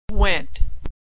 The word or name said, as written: went